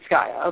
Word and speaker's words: Gaia: Or how do you pronounce her name? I'm useless at Norse mythology if it's not like... – I thought it was Gaia. – It's Gaia, okay.